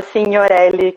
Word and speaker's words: Signorelli: Então, gente, é, eu sou Raquel Signorelli, meu nome, todo mundo pergunta, então já começo me apresentando assim. É, se escreve Signorelli, então se jogar Signorelli aí no Instagram, vocês acham todos os meus perfis, que é alguns segmentos de artes um pouco diferentes, mas fala Signorelli, que é origem italiana.